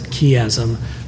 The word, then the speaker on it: chiasm: I pronounce it chiasm or a chiasmus expression.